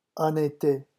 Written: en été

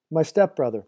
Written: my stepbrother

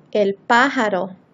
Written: el pajaro

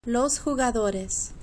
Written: los jugadores

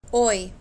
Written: hoy

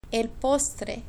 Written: el postre

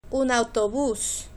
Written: un autobus

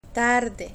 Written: tarde

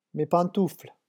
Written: mes pantoufles

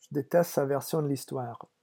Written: je deteste

